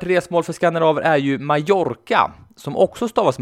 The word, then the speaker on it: Mallorca: Mallorca stavas med dubbel L och ska därför uttalas Mallorca.